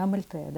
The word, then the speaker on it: Амальтея: Ударение Амальтея, да? Да, Амальтея.